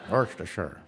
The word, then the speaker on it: Worcestershire: Anyone have a different pronunciation of the sauce? – Worcestershire. – Worcestershire. Worcestershire. You say it like that. Worcestershire. – Worcestershire.